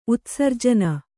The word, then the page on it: ಉತ್ಸರ್ಜನೆ: Kannada